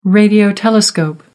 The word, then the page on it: radio telescope: American English